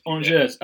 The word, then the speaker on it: Angers: So you started playing at, I'm hoping I pronounced this right, so Angers? – Yes, Angers, yes. He's my first professional team. – Angers, yeah.